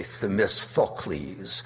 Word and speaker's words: Themistocles: I do not know how the literary sources changed, but it looks like in contemporary Athens when Themistocles was alive, however you pronounce that first TH, you should pronounce the second syllable the same way, Themistocles.